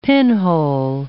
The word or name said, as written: pinhole